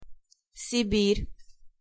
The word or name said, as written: Siberia